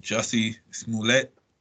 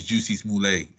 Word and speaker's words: Jussie Smollett: Jussie Smollett. – Oh yeah, little Jussie. Yeah. – You know what I mean? I can never say his name. He's always Juicy Smollett every time I say his name.